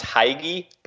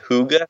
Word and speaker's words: hygge: It looks like it's pronounced Hygge, but it's actually pronounced Hygge.